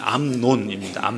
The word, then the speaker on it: 암논: 이 발음이 좀 조심해야 되는데 암논입니다.